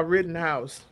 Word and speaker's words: Rittenhouse: You're talking about Rattle House, Rittenhouse. – Yeah. Rittenhouse.